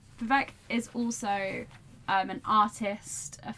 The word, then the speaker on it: Vivek: Also interesting, I'm sorry if I'm saying Vivek wrong. – I think we're saying it right. – Vivek Shraya.